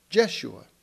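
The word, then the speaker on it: Yeshua: Both bore the same name, the name of Jesus would have been pronounced Jeshua.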